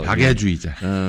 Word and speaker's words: 박애주의자: 막 발음을 이상하게 하지 말아주세요. – 박애주의자. 애야 애.